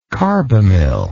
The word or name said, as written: carbamyl